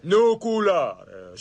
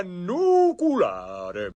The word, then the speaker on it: nucleare: Nucleare, si pronuncia nucleare.